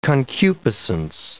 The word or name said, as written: concupiscence